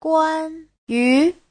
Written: guan1yu2

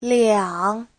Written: liang3

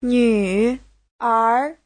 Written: nv3er2

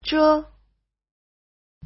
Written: zhe5

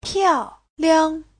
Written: piao4liang5

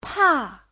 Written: pa4

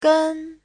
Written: gen1

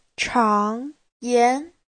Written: chang2yan2

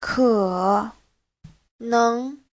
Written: ke3neng2

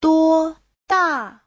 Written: duo1da4

